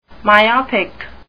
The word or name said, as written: myopic